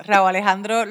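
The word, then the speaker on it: Rauw Alejandro: Ahora se escribe R-O-W-L. Raúl. – Oh, wow. Ok. No, Raúl Alejandro lo escribe R-A-U-W.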